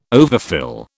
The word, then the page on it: overfill: British English